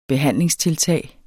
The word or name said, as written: behandlingstiltag